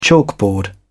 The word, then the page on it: chalkboard: British English